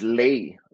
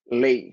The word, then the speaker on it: lei: And also, you know, simple words such as lei. It's not le, kalei, lei.